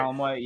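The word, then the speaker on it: Calamawy: Kalamoi, yeah.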